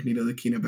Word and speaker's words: Ntilikina: But I want to see Jarrett Culver on the Mavericks, because if the Mavericks could bring Frank Ntilikina back, they could bring Jarrett Culver back.